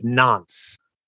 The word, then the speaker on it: Nantes: But one thing I just have to note is I, for any of our French speakers out there, I think it's Nantes. I think that's how you pronounce that. I don't think it's Nantes. – Nantes, part of Americans reporting on European sports. yeah. – Oh, now this is saying Nant. It's definitely not Nantes.